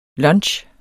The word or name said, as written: LUNCHEN